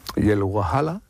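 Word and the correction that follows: hawala: Sí, la Jawala.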